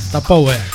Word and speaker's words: Tupperware: É Tupperware.